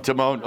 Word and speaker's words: Timon: Timon. Well, it's spelled T-I-M-O-N. – Timon. – Okay, it's Timon.